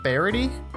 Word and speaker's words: Barryte: They have Twitter at, it's pronounced Barity, but it's spelled Barity, okay. – B-A-R-R-Y-T-E, bros.